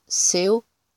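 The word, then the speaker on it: Seu: Seul se prononce Seo, pronom de traitement, contraction de Senhor, monsieur, utilisé autrefois pour marquer le respect et aujourd'hui utilisé de façon générale.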